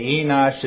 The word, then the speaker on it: Enosh: Okay, Enosh is a long E.